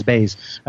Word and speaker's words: Baze: It's Baze. I remembered it rhymes with Blaze.